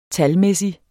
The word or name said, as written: talmæssig